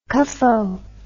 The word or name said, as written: cuffo